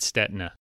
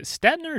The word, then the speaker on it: Stetina: It is Stetina. My ancestors come from Czechoslovakia, and then all the Italians racing in Europe would think it's Stetina. So yeah, everyone says it that way, but it's Stetina with a silent I.